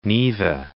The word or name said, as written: Neva